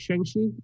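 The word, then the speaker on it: Shang-Chi: Shang Chi. Did I say that right? Shang Chi? – Shang Chi.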